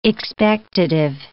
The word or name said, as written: expectative